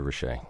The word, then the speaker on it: Rasiej: It's spelled R-A-S-I-E-J. And for those in New York who want to remember it, just think of Shea Stadium, Andrew Rache.